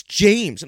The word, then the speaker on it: Jameis: I'm like, "How do you pronounce it then?" And they're like, "It's James." And I'm like, no, it's not James. There's no way that can be pronounced James.